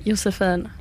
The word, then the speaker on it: Josephine: How would you say your name in, like, Sweden? Like, say... – I would say Josephine.